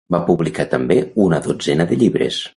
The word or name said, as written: llibres